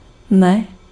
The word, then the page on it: not: /nɒt/; /nɑt/